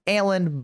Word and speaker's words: Alan: You can find me at Alan Busby. Remember, it is spelled A-L-A-N. Everyone seems to think it's A-L-L-E-N.